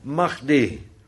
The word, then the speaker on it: Mahdi: de imam Mahdi, met een H erin die wat hard uitgesproken wordt.